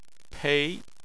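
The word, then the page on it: pei: tone 2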